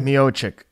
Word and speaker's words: Miocic: Miocic. How do you say him again? – Miocic.